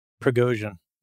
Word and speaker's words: Prigozhin: It's like, yep, that's Prigozhin. – Yeah. Prigozhin.